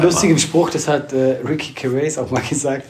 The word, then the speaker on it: Gervais: Wir haben ihn jetzt beide falsch ausgesprochen. Gervais, Ricky Gervais.